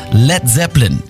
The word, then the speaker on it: Led Zeppelin: Led Zeppelin, das A in Led ließ er einfach weg, damit es nicht wie Blei ausgesprochen wird.